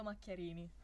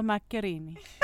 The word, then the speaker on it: Macchiarini: Paolo Macchiarini. – Paolo Macchiarini.